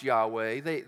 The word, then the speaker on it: Yahweh: It's pronounced Yahweh.